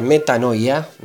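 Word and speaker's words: metanoia (μετάνοια): Bueno, es una palabra griega que se pronuncia metanoia, compuesta por dos palabras griegas, meta, cambio, y nous.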